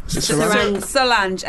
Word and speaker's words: Solange: How do I spell that person you said, Sarange? – Solange. S-O-L-A-N-G-E. – Well, I like it. – Oh, Solange. – Solange, not rather than humans getting confused with Sarange. – S-O-L-A-N-G-E. A-N-G-E.